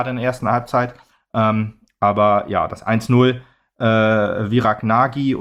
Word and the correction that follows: Nagy: oder Natsch, wie sie eigentlich ausgesprochen wird,